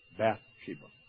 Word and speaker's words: Bathsheba: By the way, if you want to know how to pronounce or spell her name, it's B-A-T-H, Bathsheba, taking a bath, Bathsheba. So that's how you know. It's not Bathsheba, it's Bath, B-A-T-H, Bathsheba.